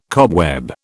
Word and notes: cobweb: British English

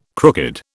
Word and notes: crooked: British English

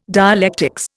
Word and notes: dialectics: Indian

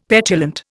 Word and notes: petulant: Indian

